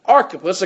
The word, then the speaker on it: Archippus: He says, and say to this guy right here, his name is Archippus, according to the way the pronunciation guide goes, there's an underline under the CH, that'd make that a K, right? – Right.